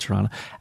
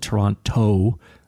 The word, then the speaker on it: Toronto: Or if you're from Buffalo, we call it Tirana. Actually, I don't know if everyone calls it Tirana. A lot of us call it Toronto. I don't know why we put the emphasis on the toe or the rana.